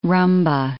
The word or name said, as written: rhumba